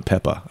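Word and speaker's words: Pepa: A good pronunciation of the Peppa. I don't know if I got that right. You got that right. – Like Peppa Pig.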